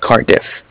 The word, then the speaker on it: Cardiff: Se escribe C-A-R-D-I-F-F. ¿Cómo different? – C-A-R-D. – Ajá, como card. And then diff, like different. – Okay. – Like card diff.